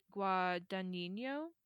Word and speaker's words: Guadagnino: The director of Call Me By Your Name, Luca Guadagnino, is maybe how that his name is pronounced. – Sounded great.